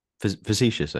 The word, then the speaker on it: facetious: Well, uh, as um, a lot of that sidestepped the question, but in fairness, my question was also um, slightly um, fascists, no, fascists, fascists. Hmm. Oh God, I can't even pronounce it. Fascist. Fascists. – No, not fascist. No, no, fascists. – Facetious. – Am I saying that right? – Facetious, I think. – Facetious. Yes.